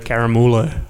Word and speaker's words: Caramulo: Caramula, almost like a caramello koala, like caramello, caramulo.